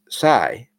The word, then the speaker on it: psi: The letter P is silent. And the next one is psi, PSI.